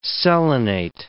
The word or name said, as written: selenate